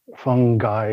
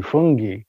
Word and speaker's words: fungi: And then there's another English prof that I know quite well who insists it has to be not just fungi, it should be fungi. But what it comes down to, you know, if you look in the dictionary, they accept both pronunciations, so that's good enough for me.